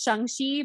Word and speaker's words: Shang-Chi: Yeah, it should be pronounced Shang-Chi, but I don't think anybody's really going to get mad if you say Shang-Chi.